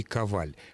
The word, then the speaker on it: Коваль: И как вы думаете, где поставить ударение фамилии писателя Юрия Коваля? – Ну, Елена Николаевна уже поставила ударение, причем правильно вообще, Юрий Коваль. Хотя совершенно нельзя исключить того, что существуют люди с такой же фамилией, но имеющие ударение на первом слоге.